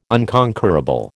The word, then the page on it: unconquerable: American English